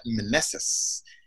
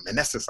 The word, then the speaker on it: Meneses: We have Miss Nanette Meneses, and I learned how to pronounce her name really well, and it originates from back east Israel, and so it sounds all awesome. So Meneses, I like saying that.